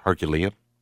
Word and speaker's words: Herculean: How do you say, is it a Herculean? – Herculean. – Herculean? I've always struggled with that one. – Probably can go both ways, but I think it's Herculean.